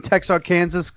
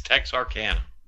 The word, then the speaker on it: Texarkana: Which, by the way, if you're talking about both sides, does that mean you are saying Texarkansas because it's pluralized? – No. – Oh, fuck. – Nope. It's Texarkana.